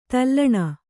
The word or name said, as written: ದಲನ